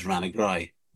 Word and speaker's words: Rathnagrew: It's spelt Rathnagru, Rathnagrew, if anybody wants to actually pin it down on a geographical map. But that's how the British spelt it back in the day. But everybody says Ranagry.